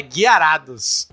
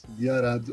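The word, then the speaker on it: Gyarados: É o Guiarados. Conceito Guiarados. Com o jeito certo de falar, é Guiarados. – Guiarados.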